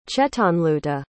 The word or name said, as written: chetanluta